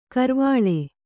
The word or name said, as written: ಕರ್ವಾಳೆ